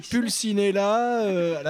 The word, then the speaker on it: Pulcinella: Et juste, pardon, petite parenthèse, mais parce que vraiment, mais c'est tellement rare et ça fait tellement plaisir, juste cet effort de prononciation sur le Pulcinella. – Ah, oui, je suis italienne. – Ah, voilà. Ah, c'est pour ça. – C'est Pulcinella, la Pulcinella.